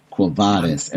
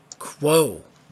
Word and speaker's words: Quo Vadis: Quo Vadis. Everybody calls her Quo. – Quo. – Yeah, like Q-U-O, yes. Like quotation marks.